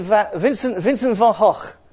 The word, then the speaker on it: van Gogh: If you're Scots, you've got an advantage because you've already got that sound in words like lock. You know, I say CK on the end, but if you're Scottish, you can say it properly. As I said, it's a bit like free and thoughtfully and thunder in the sky.